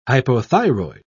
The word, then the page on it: hypothyroid: British English